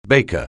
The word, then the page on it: baker: anglais